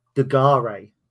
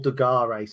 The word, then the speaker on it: De Garis: So I said Paul De Garis, but it is actually pronounced De Garis. Paul De Garis.